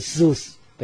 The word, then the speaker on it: Zeus: ซูสถ้าอ่านแบบเรามักจะอ่านว่าสีอุดไม่ใช่สีอุยนะสีอุดสีอุดก็ Z E U S เป็นเจ้าสวรรค์สวรรค์ของพวกกรีกเนี่ยอยู่บนยอดเขาโอลิมปัสยอดเขาโอลิมปัสเป็นที่สถิตของพวกเทพทั้งหลายมีเทพเจ้าซีอุสซุสเ